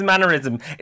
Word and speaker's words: mannerism: She means mannerism. It's not pronounced like aneurysm, yeah? It's mannerism.